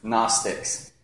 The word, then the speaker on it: Gnostics: Starts with a G, but the G is silent. Gnostics.